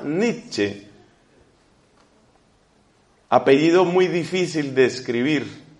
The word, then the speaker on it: Nietzsche: El apellido de ese señor se escribe de la siguiente forma. La letra N, I, E, luego la T de total. Hasta ahí llevamos Niet. Luego Z, S, C, H, E. Nietzsche, pero se pronuncia Nietzsche.